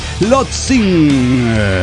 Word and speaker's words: Tlotzin: Soy Lotzin. – ¿Cómo está Lotzin? – Bueno, Lotzin. Lotzin. Y vayamos familiarizándonos con esto que se escribe T-L-O-T-Z-I-N.